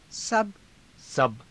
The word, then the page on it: saba: Hindi